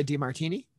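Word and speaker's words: Di Martino: And the addition of Sylvie, which is Sofia DiMartini.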